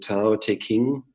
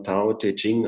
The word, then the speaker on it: Tao Te Ching: Also das heißt im Tao Te King, Ich glaube, das spricht man Tao Te Ching aus, dieses Buch.